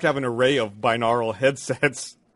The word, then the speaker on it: binaural: Binaural. – I was going to let it go. – No. – I keep saying it wrong, because that N makes no sense.